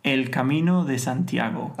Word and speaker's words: Camino de Santiago: El Camino de Santiago.